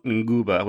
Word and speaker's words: Nguba: And that word comes from the Bantu language, and it's spelled Nguba, which is N-G-U-B-A.